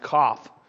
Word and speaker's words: Kaf: It actually begins with a Hebrew letter that's pronounced Kaf.